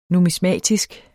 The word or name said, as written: NUMISMATIK